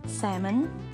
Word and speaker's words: salmon: Salmon, would is silent L.